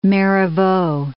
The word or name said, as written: Marivaux